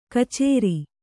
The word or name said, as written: ಕಚರಿ